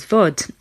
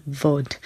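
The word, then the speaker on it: Bhòid: which is Vod, and that's spelled B H O I D.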